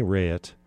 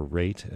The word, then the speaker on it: Raitt: Okay, you heard in order, starting from the beginning, Neil Young, Bonnie Raitt, or Raitt as it's somehow sometimes pronounced, Bonnie Raitt, and Joni Mitchell.